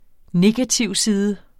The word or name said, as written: negativside